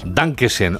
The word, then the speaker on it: Dankeschön: Dankeschön, o como se diga. ¿Lo he dicho bien? – Lo has dicho perfecto.